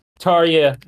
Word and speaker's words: Tarjei: that is spelled T-A-R-J-E-I. What the fuck, Norway? – And I'm using like the English like pronunciation. Like, I looked into it because I know that Norwegian pronunciations can be very difficult for English speakers. So it's not the true way that Tarja would pronounce his own name in Norwegian. But when doing an English interview, it's Tarja Bo.